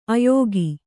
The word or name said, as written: ಆಯುಗ